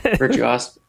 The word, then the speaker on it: virtuosic: Virtuosic. For Google. That's how you say it.